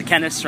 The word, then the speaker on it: Kenneths: The Kennets, right? Got it? Did I pronounce it wrong? – K-E-N-N-E-T-H-S.